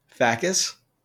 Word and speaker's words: fakes: it says Greek lentil soup or F A K E S in Greek pronounced F A H hyphen K H E S. How would you pronounce that? – Facus.